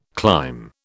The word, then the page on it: clime: British English